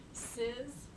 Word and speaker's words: Czys: Ciz. It's spelled C-Z-Y-S.